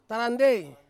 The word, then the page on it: tarandei: Ansus